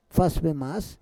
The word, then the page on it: fas vemas: Roon